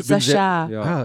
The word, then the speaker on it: Sacha: Aber Sascha, da geschrieben steht Sacha. – Ja, ist eine Variation. – Okay. – Kannte ich auch am Anfang. – Französisch, Sascha. Ich bin sehr froh, dass du das ausgesprochen hast.